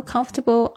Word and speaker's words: comfortable: 重音是在第一个音节上面，comfortable，comfortable，然后后面那个虽然有一个 table，但是你不要念成 table，不是 comfortable，comfortable，table。 All right, I feel comfortable on the beach，就是说我在这个海滩上感觉到很舒服、很自在，特别是讲自在的意思。